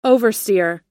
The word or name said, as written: oversteer